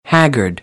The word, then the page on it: haggard: American English; ɚ